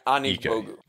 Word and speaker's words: Anigbogu: Like, I think I would be more interested in like Ike Anigbogu. Is that how you pronounce it? – Yeah, Ike Anigbogu.